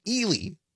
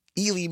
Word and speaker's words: Ely: It's pronounced Ely, Ely, Minnesota, and it's W-E-L-Y.